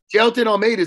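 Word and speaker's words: Jailton Almeida: And look, Jelton Almeida is a stud.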